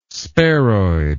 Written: sparoid

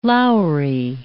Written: lowery

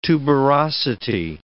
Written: tuberosity

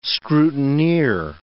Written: scrutineer

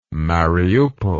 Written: mariupol

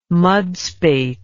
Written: mudspate

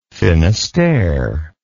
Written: finistere

